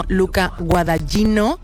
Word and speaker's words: Luca Guadagnino: Efectivamente, fíjate, esta película está dirigida, espero decir bien el nombre porque tú esto lo vas a pillar muy rápido, por el director italiano Luca Guadagnino. – Bueno, a ver, no es como lo hubiese pronunciado yo porque soy de allí, pero lo ha salvado. – No está mal, no está mal, no, un notable alto.